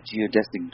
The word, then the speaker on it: geodesic: And so he is the inventor of the geodesic dome. Did I say that right? – Yeah, sure.